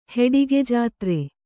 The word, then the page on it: ಹೆಡಿಗೆಜಾತ್ರೆ: heḍige jātre